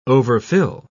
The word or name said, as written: overfill